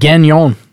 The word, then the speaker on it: Gagnon: Gagnon.